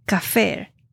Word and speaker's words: kaphar: Es equivalente al hebreo kafer, K-A-P-H-A-R, que significa cubrir.